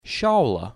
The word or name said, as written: Shaula